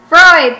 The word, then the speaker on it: Freud: I mean, how do you pronounce his name? Fraud? Freud? – Oh, Freud? – Freud, thank you.